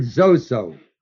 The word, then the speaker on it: sozo: The word save is the word zozo, S-O-Z-O, long O.